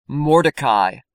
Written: Mordecai

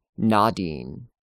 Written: Nadin

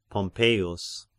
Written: Pompeius

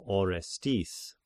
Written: Orestis

